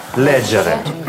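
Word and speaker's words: leggere: Здесь особенность в том, что в некоторых лицах произносится «г», а в некоторых «дже». – «Лего», «леджи», «ледже». – Да, еще раз. «Читать», «леджере».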